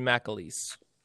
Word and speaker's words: McAleese: How do you McAleese. – say it? McAleese. Okay. – Justin McAleese.